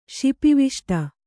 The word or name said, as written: ಶಿಪಿವಿಷ್ಟ